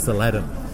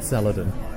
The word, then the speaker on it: Saladin: Ambie's putting the emphasis on the wrong syllable. It's Saladin. Not Saladin.